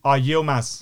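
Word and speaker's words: Yılmaz: Yilmaz.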